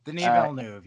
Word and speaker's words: Denis Villeneuve: I like Denis Villeneuve. That I believe is how you say his name. I could be wrong. – Denis Villeneuve, yes.